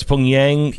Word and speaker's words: Pyongyang: How do you say the Pyongyang? – Pyongyang? – Yeah, Pyongyang. – Pyongyang. – So it's Pyongyang.